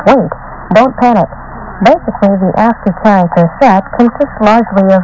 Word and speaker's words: ASCII: You may have heard the term ASCII, pronounced ASCII, from computer users.